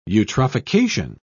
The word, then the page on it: eutrophication: British English